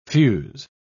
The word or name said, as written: fuse